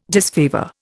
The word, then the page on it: disfavour: Indian